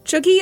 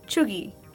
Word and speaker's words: cheugy: Chuggy is spelled C H E U G Y. – Chuggy.